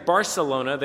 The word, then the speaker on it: Barcelona: If you were to go, like, to Barcelona, and if you kind of know how Spanish is supposed to sound, most people would pronounce the C in Barcelona, Barcelona. But in Spain, they don't do the same thing. The Spanish actually have a funny accent in Spain. They pronounce their S's, or in their case, their C's, with a lisp. So instead of saying Barcelona, they say Barcelona, Barcelona.